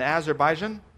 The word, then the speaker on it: Azerbaijan: There's a story of a group of Christians in Azerbaijan. Did I pronounce that right? Middle Eastern country? I see heads nodding.